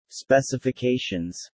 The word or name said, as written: specifications